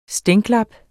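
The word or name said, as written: STÆNKLAP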